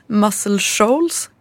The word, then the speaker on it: Muscle Shoals: Ja, men dels, dels så var det Bo Diddley-låten och sen så är den även inspelad i mm, eh, nu ska vi uttala, Muscle Shoals. – Ja, jag tror, det tror jag är helt rätt.